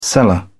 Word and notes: seller: British English

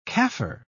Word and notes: kaffir: British English

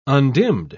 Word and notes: undimmed: British English

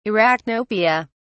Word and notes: arachnopia: British English

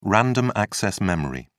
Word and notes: random-access memory: British English

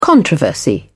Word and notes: controversy: British English

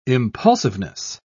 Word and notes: impulsiveness: British English

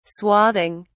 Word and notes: swathing: British English

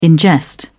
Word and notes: ingest: British English

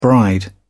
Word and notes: bride: British English

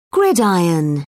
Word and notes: gridiron: British English

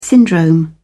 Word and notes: syndrome: British English

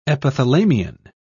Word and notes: epithalamion: British English

